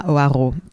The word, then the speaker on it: Hoareau: I say Emma Hoareau, but no one can have the R.